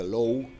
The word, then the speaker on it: Loewe: Eh, bueno, el premio Low, Loewe, creo que se pronuncia Low, es un premio que, se otorga a un, trabajo poético, en español, por supuesto.